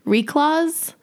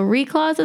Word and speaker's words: Reklaws: They're called if I can say it correctly, it's Reclaws. So it's brother and sister, Stuart and Jenna from Ontario, Canada. And so Reclaws is spelled R-E-K-L-A-W-S.